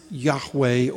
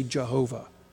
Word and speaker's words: Yahweh: We might pronounce it Yahweh, or in the Middle Ages, we used to say Jehovah.